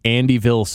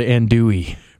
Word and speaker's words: andouille: and he ordered the Andyville sausage, not knowing how to pronounce the word andouille.